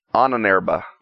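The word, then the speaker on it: Ahnenerbe: And for part 6, we're looking at tell me if I pronounce this wrong, and maybe you don't know either, the Ahenerbe? – Ananerba.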